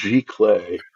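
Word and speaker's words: giclée: It's pronounced Geikly. But I think it's one of these words like Haagen-Dazs that somebody just made up to sound, you know, foreign and exotic.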